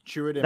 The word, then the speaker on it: Chirrut: Chirrut? Is that how you say it? – Yep. Chirrut.